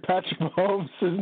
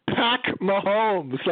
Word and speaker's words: Patrick Mahomes: Do you remember that, Greg, when Kaplan butchered Patrick Mahomes' name? – No, you mean last week? – Yeah. Kaplan called him Pack Mahomes.